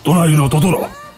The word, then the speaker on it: Tonari no Totoro: A escondidas Bueno, la película se llama Mi vecino Totoro, que en japonés se llama Tonari no Totoro, ¿vale? – Pronuncia bien. – Tonari Totoro. – Pronuncia bien en japonés. – Tonari no Totoro. – Eso, muy bien.